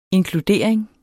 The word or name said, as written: inkludering